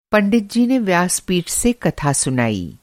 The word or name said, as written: व्यासपीठ